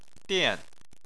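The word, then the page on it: dian: tone 4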